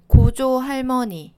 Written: 고조할모니

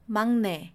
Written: 막내

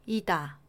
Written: 이다

